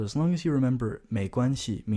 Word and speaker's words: 没关系: 没关系。